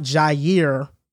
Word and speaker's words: Jair: He has the best coverage grade of any linebacker, and even without Jair...